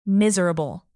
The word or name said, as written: miserable